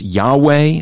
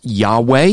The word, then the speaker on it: Yahweh: Some people pronounce this as Yahweh because they're looking at just the consonants.